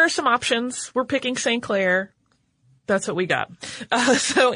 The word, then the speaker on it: St. Clair: In 1788, Arthur St. Clair, who sometimes you'll hear it pronounced more like Sinclair, and there's some debate about the correctness of which one, but we're going with St. Clair for this episode.